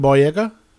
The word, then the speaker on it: Boyega: And part of this post-apocalyptic, in one of these areas, we have John Boyega.